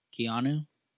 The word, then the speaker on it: Keanu: How do you say his name? – Keanu.